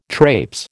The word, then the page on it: trapes: American English